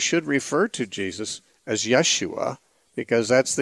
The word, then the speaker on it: Yeshua: Jesus is really the same as Yeshua, the difference being that there's an SH in Yeshua because the Hebrew language had SH. The Greek language does not have the SH sound, so it's just the S sound. Jesus or Yeshua would be more closely similar.